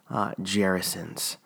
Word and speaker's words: Gerasenes: So Mark says that Jesus and his disciples crossed the Sea of Galilee and landed in a place called Gerasenes. And that is how you say the name of the place, actually, because I looked it up on Google, and because I wasn't 100% sure how to say it.